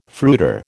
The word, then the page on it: fruiter: American English